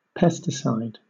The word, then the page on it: pesticide: British English